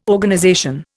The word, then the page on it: Organization: Indian